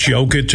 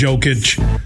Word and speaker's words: Jokic: They all call him the Joker because everybody mispronounces his name the wrong. It's Jokic. It's Jokic.